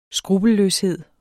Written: skrupelløshed

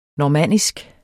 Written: normannisk

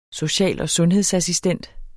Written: social- og sundhedsassistent

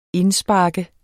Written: indspark

